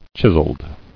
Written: Chiseled

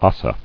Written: Ossa

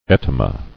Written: Etyma

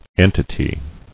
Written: Entities

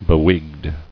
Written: Bewigged

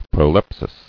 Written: Proleptical

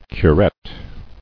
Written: Curette

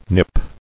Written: Nip

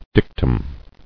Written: Dictum

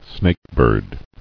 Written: Snakebird

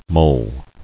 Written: Mulling

